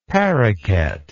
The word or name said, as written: paroquet